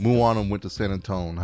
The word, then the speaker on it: Moana: But anyway, so it was a Moana.